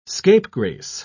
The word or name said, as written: scapegrace